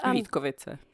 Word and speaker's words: Vítkovice: Vítkovice. – Vítkovice, that's how you say it in Czech.